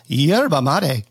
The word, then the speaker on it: yerba mate: We've been pronouncing it wrong. It's yerba mate.